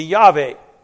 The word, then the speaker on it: Yahweh: The proper pronunciation would be Yahweh, God.